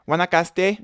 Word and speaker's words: Guanacaste: Guanacaste.